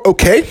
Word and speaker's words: Okey: And it's called Okey. And you're like thinking OK, like OK, like it's spelled now. It's actually spelled O K E Y.